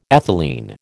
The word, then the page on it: ethylene: American English